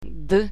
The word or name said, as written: d